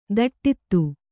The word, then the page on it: ದಟ್ಟಿತ್ತು: kannada; daṭṭittu